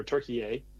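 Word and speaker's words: Türkiye: Turkey or Turkiye. Mm-hmm. As the proper pronunciation.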